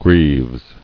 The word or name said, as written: Greaves